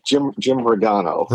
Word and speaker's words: Jim Rigano: Jim Rigano.